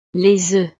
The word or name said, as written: les oeufs